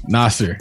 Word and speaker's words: Nasar: Nasar.